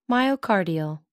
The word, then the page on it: myocardial: American English